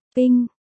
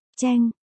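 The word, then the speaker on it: cheng (秤): Bạch Dương, thiên bình, ping, từ đó đọc là, cheng, chòm thiên bình.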